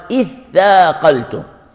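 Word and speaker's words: اثّاقلتم: كلمة ثاقلتم أصلها تثاقلتم، قلبت التاء المثنى ثاء مثلثة لتقارب المخرجين طلبا للإدغام، أصلها تثاقلتم لكن فقلبت إلى ثاء، فقال إثاقلتم إثاقلتم إثاقلتم.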